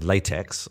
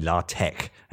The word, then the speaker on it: LaTeX: So a lot of people may mispronounce it as LaTeX or LaTeX or whatever, but it's actually LaTeX. And then tex is tech.